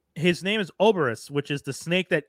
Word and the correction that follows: Ouroboros: I thought it was Ouroboros. I thought Ouroboros. That's how you pronounce it, but sorry. I could be wrong. Listen to me on pronunciation. – No, you are right. I forgot. It is Ouroboros.